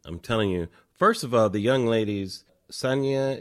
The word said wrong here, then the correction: Sanaya: is that how you pronounce it? – Sanaya Sidney and Demi Singleton.